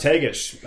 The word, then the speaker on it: Tagish: Every year, the Carcross, is it the Tagish Management? Is that how you say it? – Carcross Tagish Management Corporation has to find funding from other sources to keep the S2S program running.